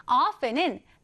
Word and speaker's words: often: 자, 발음할 때는요, 두 가지 방법이 있습니다. 첫 번째는 이 T를 살려서 often. 두 번째는 T 발음을 생략한 채로 often이라고 하는데요. 여러분은 often, often 어떤 것이 더 마음에 드시나요? 사실은 두 번째 T를 생략한 채로 발음하는 것이 훨씬 더 흔히 사용되긴 합니다. 그래서 오늘은 often으로 발음해 볼게요.